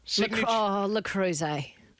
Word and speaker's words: Le Creuset: Is it, how do I say it, Le Creuset? – Le Creuset.